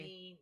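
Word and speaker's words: jeen-yuhs: Genius. – Right? Genius. Yeah. So it's Genius, but it's a play. It's like it's the phonetic. Like the play on how to say it.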